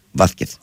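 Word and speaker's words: Vázquez: Ας πούμε, το έχω πει πολλές φορές, ας πούμε, κανονικά τους Ισπανούς θα έπρεπε να τους λέμε όλους με θήτα. Βάθκεθ.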